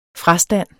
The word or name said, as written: frastand